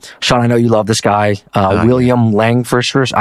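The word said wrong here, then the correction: Langewiesche: I can never pronounce his last name. – Langovici, yeah, he's amazing.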